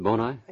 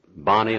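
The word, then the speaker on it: Boni: Is that how you pronounce it, Bonai? – Yes, that's right. – I've heard it Bonnie and I've heard it Bonai. – It's hard. It's somewhere in between, I think. But Bonai, it was Bonai and Liberite was the firm. At least that's the way I've always pronounced it. I don't have scholarly proof that that's the right way.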